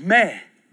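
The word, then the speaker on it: meh (μή): And the other word for no is meh, M-E, long E, that is used in all other moods except the indicative, imperative, optative, imperative, optative, subjunctive.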